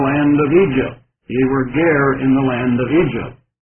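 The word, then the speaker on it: ger (גֵּר): It's the Hebrew word ger, pronounced in English as we would pronounce G-A-R-E.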